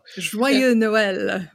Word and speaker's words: Joyeux Noël: Joyeux Noël.